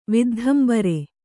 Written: ವಿದ್ಧಂಬರೆ

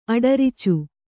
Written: ಅಡರಿಚು

